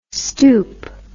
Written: stoep

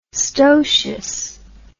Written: stotious

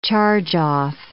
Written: charge-off

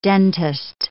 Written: dentist

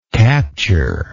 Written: capturer

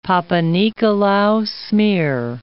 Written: Papanicolaou smear

